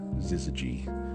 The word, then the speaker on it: syzygy: I think we're all just looking for this term, syzygy. I had to look up how to pronounce that, by the way. It's spelled S-Y-Z-Y-G-Y.